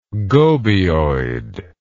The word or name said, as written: gobioid